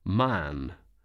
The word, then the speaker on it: Mahon: He had a Roman nose, a snow-white long beard, and his name was Mahon. But he insisted that it should be pronounced Man.